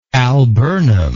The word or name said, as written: alburnum